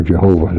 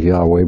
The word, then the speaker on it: Yahweh: Yahweh, the Tetragrammaton, J-H-V-H or Y-H-W-H, probably pronounced, most people now think Yahweh, but Jehovah's been used so long I hesitate to drop it, and we can't be absolutely sure.